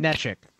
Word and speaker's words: Neshek: Neshek? How do you say it? It's Neshek, right? – Neshek. – Yeah, that was Neshek.